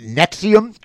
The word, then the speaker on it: NXIVM: Smallville actress charged with sex trafficking for alleged involvement in, was it Nexium cult? I think it's how it's pronounced. NXIVM. You know, because they want the Roman numeral in there. Even though N is not a Roman numeral.